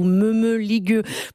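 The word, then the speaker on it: mămăligă: Voilà donc Mamaliga ou Meumeligueux, pour le prononcer à la romaine, de Zoé Barthélémy.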